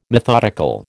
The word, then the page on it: methodical: American English